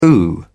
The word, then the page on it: ooh: British English